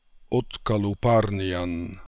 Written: Otkalooparnian

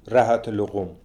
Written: Rahat Lokoum

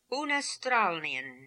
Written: Oonastralnian

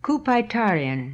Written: Kupaitarian

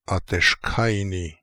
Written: Ateshkaini

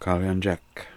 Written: Kaliandjek

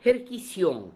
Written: Herkission

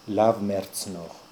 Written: lav mertz nokh

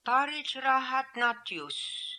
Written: Parijrahatnatioose

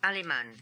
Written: Aliman

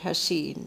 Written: Hassein

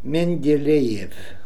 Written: Mendelejeff

